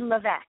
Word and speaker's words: Levesque: I'm fantastic, and thank you for asking because uh, it's actually Levesque. It rhymes with Beck, yes.